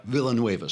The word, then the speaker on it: Villanueva: One of them comes out, Alejandro Villanueva, who is one of, is that how you pronounce it? Villanueva, sorry. Alejandro Villanueva comes out, and he's an offensive lineman who is one of three, I believe, veterans in the NFL.